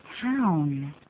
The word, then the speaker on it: town: 好，hometown，那个 town 的念法，有人念成好烫的烫，其实有点差别，对不对啊？ – 当然有差别，要念个 ow 的音，town， hometown，而不是烫啊，就可变成 hometown，那一听呢就是这个发音还不行，所以我们再念一下。